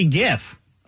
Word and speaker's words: GIF: What about GIF or JIF? See, this is why you're wrong about the pronunciation. – It's GIF. – It's mm-hmm. GIF. – Yeah, it's a GIF. – We used to say JIF.